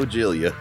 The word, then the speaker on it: Giglia: Joe Giglia.